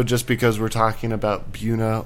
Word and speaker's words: Buena: Natives call it Buena. – I've always said Buena. I've lived here my whole life. – Because that's the appropriate Spanish.